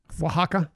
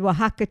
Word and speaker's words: Oaxaca: Oaxaca? Yeah, and Oaxaca cheese. Make me sound smart. Yes. Very nice. Oaxaca cheese.